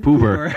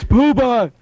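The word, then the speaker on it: Grand Puba: No, that was him just pretty much ripping off Grand Pooba and pronouncing it Poober. – Poober. – He was like, oh, Grand Poober. Really like that, right? I'm like, it's Pooba.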